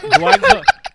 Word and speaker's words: jollof: Wait, how do you pronounce that? – Jollof. That's like a motherfucker coming in here calling me, bro. Come on. – That's what you said. – It's jollof.